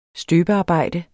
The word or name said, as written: støbearbejde